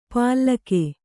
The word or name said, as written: ಪಾಲ್ಲಕೆ